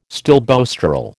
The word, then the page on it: stilboestrol: American English